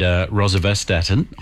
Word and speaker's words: rosuvastatin: He went to a cardiologist, put him on ezetimibe and rosuvastatin. Yep. I think I said that right. – You did.